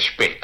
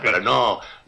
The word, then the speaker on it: Spee: Dígame usted cómo se dice, Spee, ahí está. Spee. Para no ponerlo en inglés, me dice.